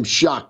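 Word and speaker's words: Shough: Some people pronounce his name Schlaf, I guess. But I saw his Twitter. It said his last name Shuck, Tyler Shuck.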